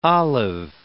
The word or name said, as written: olive